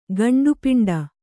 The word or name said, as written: ಗಂಡುಪಿಂಡ